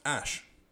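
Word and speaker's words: Æ: And then he says that the AE is pronounced Ash.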